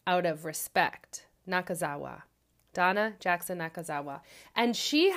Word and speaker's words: Nakazawa: I want to pronounce it correctly. I just practiced. Nakazawa. I think that's how you do it.